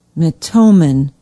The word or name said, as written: metomen